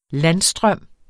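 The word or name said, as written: landstrøm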